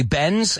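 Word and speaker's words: Benz: Now, if you say it quickly, bends and bends, sounds exactly the same. So what does that mean? That means that that D, cuando ponemos esa D, cuando ponemos la S después, no pronunciamos ese, esa D. Listen to it, right?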